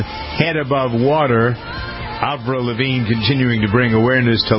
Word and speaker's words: Lavigne: Avril Lavigne, yeah.